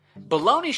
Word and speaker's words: bologna: You spell it B-O-L-O-G-N-A? Bologna? Baloney should be spelled B-A-L-O-N-E-Y, baloney. But there's a G in it.